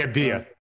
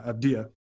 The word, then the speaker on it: Avdija: Denny Avdija. – Yeah, Avdija.